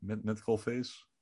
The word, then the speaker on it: Metcalfe: Metcalfe's? – Metcalf. – Ja. Metcalf's. Ja, ik denk graag aan die pindakaas. Hè? Dus... – Nou ja, als het eindigt op FE, dan doe je de E niet uitspreken.